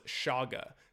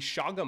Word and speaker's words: chaga: And oh, one last thing I did not talk about enough was shaga. So the shaga mushroom, the medicinal mushroom shaga it's spelled C-H-A-G-A.